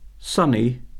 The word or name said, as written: sunny